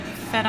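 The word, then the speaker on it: fête: I'm drinking the Grand Fete. Fete? F-E-T-E? – Oh, fete. Fete. Like a party. Isn't that the French word for party? – Is it? – Like fete en blanc?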